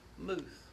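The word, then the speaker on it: muth (מות): The word death in the Hebrew is the word muth. It's spelled M-U-T, but it's pronounced muth. Muth, all the way through the Bible, is the demon of death.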